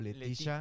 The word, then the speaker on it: Letitia: Letita o Letitia o Letitia.